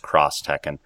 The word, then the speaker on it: Street Fighter X Tekken: Cross Tekken, which if you read it says Street Fighter X Tekken, I guess it is pronounced Cross Tekken.